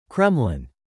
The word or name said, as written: Kremlin